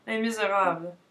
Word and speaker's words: Les Misérables: Pronounce it right. Come on. – Les Misérables.